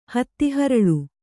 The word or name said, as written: ಹತ್ತಿಹರಳು